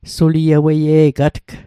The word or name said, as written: sulieweie'gatg